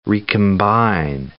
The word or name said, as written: recombine